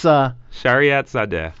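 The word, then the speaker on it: Shariatzadeh: You might be able to pronounce his last name. It sounds like it's... – Shariat Sadeh. – Very good.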